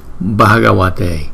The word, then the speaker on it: Bhagavate: Chữ B và chữ H chúng ta sẽ đọc là B, B. Nếu mà chữ B không thì chúng ta đọc là B. Nhưng chữ B đính kèm với chữ H thì chúng ta đọc là B. Cái hơi trong miệng chúng ta đưa ra nhiều một chút. B, B. Bhagavate, Bhagavate, Bhagavate.